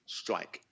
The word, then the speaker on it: STRYYK: Yeah, so first of all, it's spelled, just for information's sake, STRYYK, Strike.